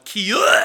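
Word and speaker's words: kia (קיא): The Hebrew word, if I'm polite, I'll say kia, which in Hebrew is pronounced kia.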